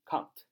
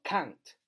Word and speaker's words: can't: can't，读作 can't can't 啊，重音要读重一点 can't 啊，发那个蝴蝶音，I can't。